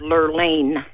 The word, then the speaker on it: Lerlean: It's pronounced Lerlean. – Lerlean. Now, that is a cool name. Isn't that cool? – How do you spell that? That's kind of cool. – It's L-E-R-L-E-A-N, Lerlean.